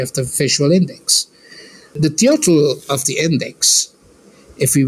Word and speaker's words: Teotl: The deity, the rock art, you know, the Nahuatl word for it, since we are closer to this context, the Nahuatl word for the divine spirit is Teo, like T-E-O-T-L, Teotl. – Teotl. – Teotl, yes. Teotl, in Anglophone pronunciation, it's more like Teotl.